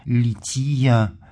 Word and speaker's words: лития: Что касается самого термина «лития», или, если с церковнославянским ударением произносить «лития», то в православной энциклопедии можем прочитать, что по-гречески это слово, от которого наше название данной части богослужения происходит, по-гречески читается «лити», и читаем в статье из православной энциклопедии, что, цитата, это «богослужение или его часть, предполагающее шествие по храму или вне его».